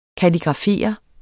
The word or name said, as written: kalligrafere